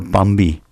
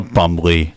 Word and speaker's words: Bumby: And before that, you heard, of course, I mispronounced it, UBT, the Bumby song. – The Bumby song. – Yeah, not the Bumbly.